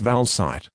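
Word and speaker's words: Valcyte: This is the consumer medicine information for Valsite, powder for oral solution, pronounced Valsite, contains the active ingredient valganciclovir.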